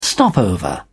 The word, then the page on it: stopover: British English